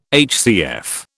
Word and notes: HCF: British English

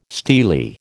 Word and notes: steely: American English

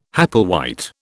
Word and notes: Hepplewhite: British English

